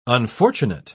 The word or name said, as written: unfortunate